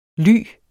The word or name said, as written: ly